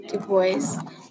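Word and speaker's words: Dubose: Jem gets offended when Miss, how do you say that? – Du Bois calls Atticus rude things, and so he decides to cut off the tips of her camellia bushes.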